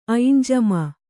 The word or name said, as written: ಐನುಜಮೆ